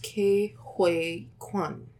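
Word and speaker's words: Ke Huy Quan: I believe it is pronounced Ke-Huy Quan.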